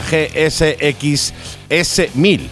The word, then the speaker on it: GSX S1000: la GSX S1000.